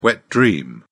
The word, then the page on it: wet dream: British English